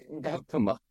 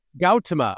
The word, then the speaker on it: Gautama: Did I pronounce that correctly? – Gautama. Yeah. – Gautama.